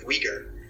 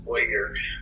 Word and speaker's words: Uyghur: Our first year we were working with Uyghur, which I'm actually kind of pronouncing wrong. I think it's more like Uyghur, but this is a language that's spoken in China, in the Xinjiang region, which is in the northwest.